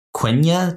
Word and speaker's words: Quenya: Also, why did the elves feel the need to change their language from Quenya to Sindarin?